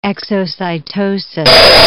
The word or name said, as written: exocytosis